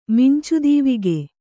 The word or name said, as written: ಮಿಂಚುದೀವಿಗೆ